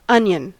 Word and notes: onion: /ˈʌnjən/

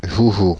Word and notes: toy: /tɔɪ/; [tɔɪ]

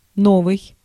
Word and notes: новый: IPA: [ˈnovɨj]; novyi

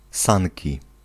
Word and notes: sanki: /ˈs̪an̪kʲi/

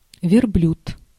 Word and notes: верблюд: vʲɪrˈblʲʉt; verbljud

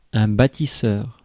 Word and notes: bâtisseur: [ba.ti.sœʁ]; /bɑ.ti.sœʁ/

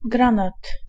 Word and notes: granat: /ˈɡran̪at̪/

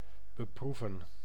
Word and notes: affliger: France; /a.fli.ʒe/

